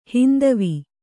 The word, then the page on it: ಹೈಂದವ: kannada